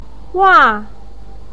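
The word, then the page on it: wa: tone 4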